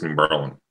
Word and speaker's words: New Berlin: It's New Berlin. It's New like Berlin. You don't pronounce the I, it's just LN.